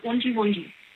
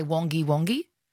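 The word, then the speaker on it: Wangi Wangi: One last night, Wonji Wonji. – Wonji Wonji. Oh, did I mispronounce that? – Last night. – Did I? Last night, did I not say Wonji Wonji? Did I say Wongi Wongi? – Yeah.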